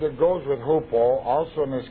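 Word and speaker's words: hupo (ὑπό): Now there's a Greek preposition which means under, and that's hupo. That's not the way it looks in Greek, but that's the way it's pronounced. Hupo.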